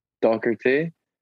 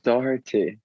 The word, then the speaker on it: Doherty: Well, like now I just say either like how you said it or I say Doherty because it's pronounced Doherty, but it's just too hard for people.